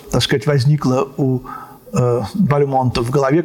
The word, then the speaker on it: Бальмонт: Это певец, тенор встретил у него Легран Константин Бальмонтин, великого Константина Бальмонта. В другом месте Прокофьев пишет, что именно от самого Бальмонта он узнал, что фамилию нужно произносить с ударением на последний слог по-французски.